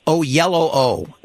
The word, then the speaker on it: Oyelowo: You are interviewing David Oyelowo.